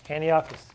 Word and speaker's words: Antiochus: You allowed, and I know I'm saying that name wrong. I say it. Antiochus. Antiochus. Got to think of the town of Antioch.